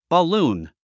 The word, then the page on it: balloon: bəlúːn; バルーン